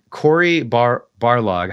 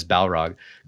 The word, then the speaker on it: Barlog: It's sort of like Corey Barlog, I keep like wanting to pronounce it as Balrog.